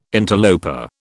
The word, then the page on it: interloper: British English